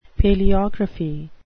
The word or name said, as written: paleography